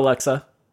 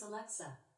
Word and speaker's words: Alexa: Say hello, Alexa. Say hello. – I pronounce Alexa. Alexa. The stress is on the middle syllable.